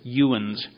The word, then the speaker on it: yinz: They don't say "you all" or "you" or "yous." They say "yins." It's "yuins." And they spell it Y-I-N-Z.